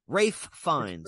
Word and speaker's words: Fiennes: Ralph Fiennes is his name, how he pronounces it, but you're right with Ralph Fiennes. I mean, it's spelled that way phonetically. Well, someone said Rafe. – Rafe Fiennes. Rafe. Yeah, Rafe Fiennes.